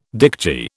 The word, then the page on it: dicty: British English